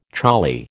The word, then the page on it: choli: American English